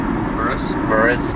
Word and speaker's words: Buress: Buress? Is that how you pronounce it? – I think that's how you pronounce it. How do you say it? – Buress. – Hannibal Buress.